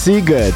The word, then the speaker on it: Sziget: Bo to jest bardzo ważna rzecz, bo pisze się Sziget, a wymawia się Sziget.